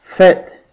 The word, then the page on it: festival: American English; /ˈfɛstəvəl/